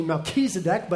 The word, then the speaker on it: Melchizedek: Melchizedek, I pronounce it slightly incorrect. It's actually Melchizedek, but that's harder to say.